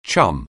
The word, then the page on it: chum: anglais